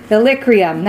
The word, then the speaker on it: Illyricum: Illyricum, that's what it is.